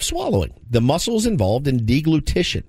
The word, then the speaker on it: deglutition: Deglutition. – You ever heard that one before? Deglutition.